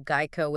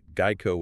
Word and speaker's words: Geico: And yes, make sure you're pronouncing that Geico with a hard G. – Right, Geico with a hard G.